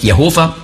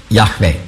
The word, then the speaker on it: Jahwe: Das also nicht Jehova, sondern Yahweh die richtige Aussprache ist. Nicht Yahweh. Yahweh ist ein hörbares H.